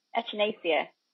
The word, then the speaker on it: echinacea: It's not pronounced how it reads, because I'd have read that echinacea. – Echinacea. I think it's pronounced echinacea.